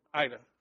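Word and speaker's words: Aida: We went down to, what is it, how do you pronounce it, the Ida refugee camp? Ada? Refugee? Ida, right.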